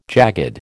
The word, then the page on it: jagged: American English